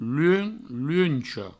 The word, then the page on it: luench, luencha: provençal; luen, luencho